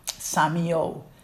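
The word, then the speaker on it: semeioo: And in group three, the next example is called Samio, and it is spelling S-E-M-E-I and two O's, O-O, and it's pronounced S-A-Y-M-I-O, Samio.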